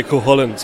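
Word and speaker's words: Cúchulainn: Up in the north, they say Cuchulainn, so there's a lot of ways to say it. Don't worry. I've heard other people call it Cuchulainn, so don't worry.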